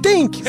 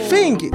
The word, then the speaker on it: Things: Things, Errou! Things.